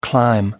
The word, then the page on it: climb: American English